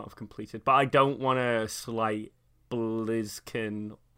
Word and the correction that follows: Blaziken: or Combusken. – Blaziken. – Yeah, thank you. You know the proper pronunciation.